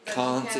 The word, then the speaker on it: Kant: It's kind of formally acknowledged that, you know, in the English language, Kant is pronounced Kant.